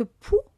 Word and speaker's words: pols: Parce que poux, figurez-vous, que on le dit poux, mais ça s'écrit POLS, et ça se dit poux.